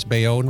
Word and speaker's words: Bayonne: Did I pronounce Bayonne right? – Yes, you did.